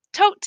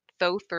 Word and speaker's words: Thoth: So I'm going to be saying tote. I like that more than thoth or toth or thoth, but obviously you're going to say it.